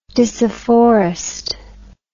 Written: disafforestment

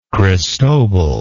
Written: cristobal

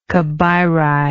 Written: Cabiritic

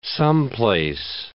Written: someplace

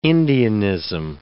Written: Indianism

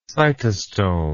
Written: cytostome

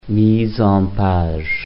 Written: mise-en-page

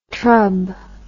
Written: trub

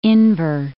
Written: Inver Grove Heights

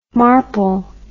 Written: Marple

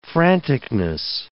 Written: franticness

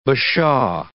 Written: bashaw

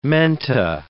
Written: menta

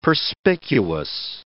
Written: perspicuous

